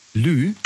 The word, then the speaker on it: Lieu: Lieu. Also Lieu, das schreibt man, also L-I-E und dann U, Lieu.